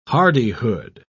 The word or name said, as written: hardihood